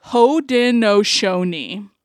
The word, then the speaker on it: Haudenosaunee: Haudenosaunee. – Haudenosaunee.